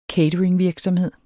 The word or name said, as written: catering